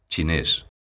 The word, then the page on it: chinés: galega